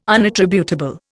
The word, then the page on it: unattributable: Indian